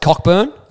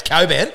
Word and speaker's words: Cockburn: Their training base is at Cockburn, as Billy Brownless would say. – I believe it's pronounced Coburn.